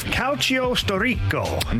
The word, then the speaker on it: Calcio Storico: but have either of you heard of Calcio Storico? – No, I haven't, but I loved that pronunciation.